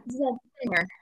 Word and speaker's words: Zenger: Yeah, Zenger. Zenger.